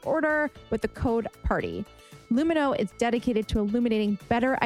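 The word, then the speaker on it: Lumineux: So there's a reason why they pronounce their name Lumino.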